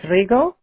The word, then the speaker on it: Rego: It's pronounced Selene, but it's spelled S-E-L-E-N-E. And my last name is Rego. R-E-G-O.